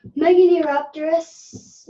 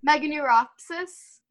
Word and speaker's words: Meganeuropsis: Megadiroptorus. – Megadiroptorus. – Say it again. – Meganeuropsis.